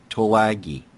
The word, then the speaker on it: Tulagi: And the connection to that is several things that are connected would be the Tasmania sighting and the formation over Tulagi. Did I say that correctly? – Right, yes.